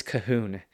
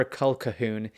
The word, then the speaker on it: Colquhoun: And I did get back from Neil King that it's always Cahoon, even if it's spelt Colquhoun, which in the United States we do have that pronunciation. It's never Culcahoon.